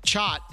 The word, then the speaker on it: Chot: That's from Chot, pronounced like yacht.